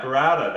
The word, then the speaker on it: burrata: Brittany said in the comments that burrata, is that how you say it? – Yeah, burrata.